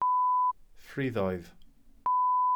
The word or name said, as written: ffriddoedd